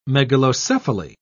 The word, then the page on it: megalocephaly: British English